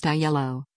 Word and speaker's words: Aiello: Aiello, pronounced Aiello, died Thursday night after a brief illness, said his publicist, Tracy Miller, who runs Tracy Miller & Associates.